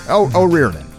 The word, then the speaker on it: O'Riordan: Dolores O'Riordan, how you pronounce her? – O'Riordan.